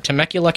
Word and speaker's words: Temecula: Natalie from Temecula, California.